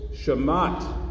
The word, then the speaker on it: shemat: I also like that it rhymes with the Hebrew word for Sabbath, Shabbat. It's not a relationship between the two words, but I like it. Shemat Shabbat.